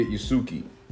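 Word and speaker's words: Yasuke: Oh, like, Yasuki? – Yeah, Yasuki.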